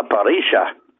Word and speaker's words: Parrilla: The double L down there is pronounced S, so it's La Parrilla di Julian.